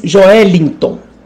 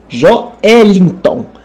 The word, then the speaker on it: Joelinton: This dude that plays for Newcastle, you are pronouncing it all wrong. His name is Joelinton. Joelinton.